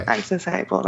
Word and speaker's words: AccessAble: AccessAble, that's right.